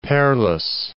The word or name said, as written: Perlis